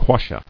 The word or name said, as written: Quassia